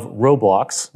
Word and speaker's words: Roblox: and individuals like the CTO of Roblox.